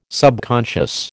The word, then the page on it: subconscious: American English